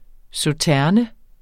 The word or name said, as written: sauternes